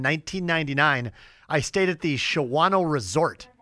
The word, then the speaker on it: Shawano: I greatly appreciate you coming to the program while you are enjoying your family and fishing bass on Shawano Lake in eastern Wisconsin. – You even said it correctly. – I used to say Shawano. – Everybody says Shawano.